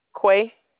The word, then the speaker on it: Qua: This is Quay, Q-U-A. – S-Q-U-A? – Yes, just Q-U-A. – Oh, Q-U-A? How do you pronounce yes, ma'am. – That? What? – Quay.